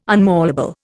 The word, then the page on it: unmalleable: Indian